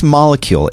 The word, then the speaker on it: Molekule: It was this molecule. It's spelled M-O-L-E-K-U-L-E. – K-U-L-E.